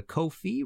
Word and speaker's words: Ko-fi: If you like the show and want to support future episodes, please go to Ko-fi, rhymes with no fee, .com/MartinPopoff.